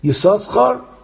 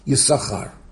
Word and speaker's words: Yisoschar: And the Rav mentions that the Minigin Valojin, we always have this discussion in Kriyas HaTorah especially, how do you pronounce it? Yisoschar or Yisachar? That the Minigin Valojin was to pronounce it Yisoschar until this Parsha, Parsha Pinchas. And afterwards, albeit the same spelling is the same, it's pronounced Yisachar.